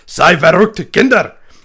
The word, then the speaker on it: Sei verrückt, Kinder: Sei verrückt, Kinder! And I'm probably saying it completely wrong. – That pronunciation is spot on.